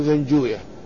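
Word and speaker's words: زنجويه: طبعا نحن نعرف أن مثل هذه الكلمات التي تنتهي بويه وتختم بويه للعلماء فيها رأيان، الرأي الأول وهو رأي أهل اللغة ينطقونها مختومة بويه كزنجوي ونفطوي ودرستوي وراهوي وغير ذلك، وأما أهل الحديث فإنهم ينطقونه بغير ذلك النطق، ولأنهم لا يتعاملون مع كلمة وي، ولأن كلمة وي اسم من أسماء الشياطين، ولذلك هم ينطقونه رويا كقولهم نفطوية وكقولهم إسحاق بن راهوية، وهكذا هنا ابن زنجوية.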